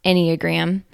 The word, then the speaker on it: Enneagram: Yes, it's a funny word, pronounced Enneagram, but it's not at all a strange system.